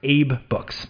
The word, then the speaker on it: AbeBooks: They're founded in Vancouver, Canada, and it is actually pronounced Abe Books. A-B-E is just Abe, like the name, Abe Books.